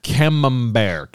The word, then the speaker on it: Camembert: I'm going to give him a camembert.